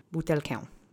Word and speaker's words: butelkę: So I'd rather teach you butelkę. Okay. Accent is on the middle syllable.